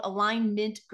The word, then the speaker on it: Alignmint: So the company is called Alignmint Growth Strategies. And I say it that way because it's spelled Align, M-I-N-T.